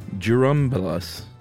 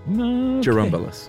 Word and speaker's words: Churumbelas: Churumbelas. – Well, is it a DJ? – It's a CH pronounced like a JU, he says. – Churumbelas.